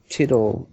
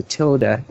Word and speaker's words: tilde: I was trying to figure out what the tittle meant. Is that how you say it? Tittle, title, or how is it pronounced? – Tilde. – Tilde, there you go.